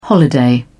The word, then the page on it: holiday: British English